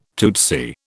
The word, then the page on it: tootsy: British English